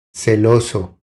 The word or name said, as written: Celoso